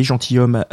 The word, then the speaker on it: gentilshommes: On a une page qui s'appelle Les Gentilhommes, comme ça se prononce avec un S à gentil et un S à homme.